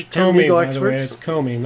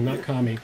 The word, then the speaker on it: Comey: Trump's lawyer, Comey, violated executive privilege. – It's Comey, not Comey.